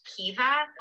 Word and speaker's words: Caoimhe: It's pronounced differently depending on where in Ireland you are. And where I am, it's Cuiva, but I know in other places in Ireland, Cuiva. It's pronounced Kiva. All the Cuivas I know are Cuivas. Cuiva is spelled C-A-O-I-M-H-E.